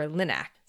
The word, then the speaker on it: LINAC: But then the physicists developed something called the linear accelerator, or LINAC. I think that's how you say it. – Sounds right.